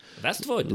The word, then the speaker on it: Vestvood: Vestvood, like Westwood, but with V's, because Persians can't pronounce their W's.